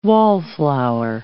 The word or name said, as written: wallflower